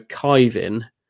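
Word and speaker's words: Caoimhín: And they're spelling it Quivine, which I would either go Kyvin or Quivin. But I think Kyvin.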